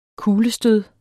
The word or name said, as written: kuglestød